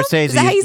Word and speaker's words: Scorsese: Scorsese.